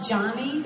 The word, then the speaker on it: Joni: It was a tearjerker about, it was called Johnny, but it's spelled J-O-N-I, about a girl who dove off and broke her neck and drew things with her mouth.